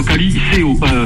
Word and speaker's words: Caliceo: Cali, C-A-L-I. – Oui. – Et plus loin, C-C-D-A-O. Caliceo. – Caliceo. Caliceo. Ah ouais. – Non, Caliceo. Après les C, c'est dit E-O. E-O, je me suis trompé. E-O. Caliceo. – Oh, ça me rappelle une chanson. I-A-N-I-C-O. – Ottawa. Ottawa avec des I-S-E-O.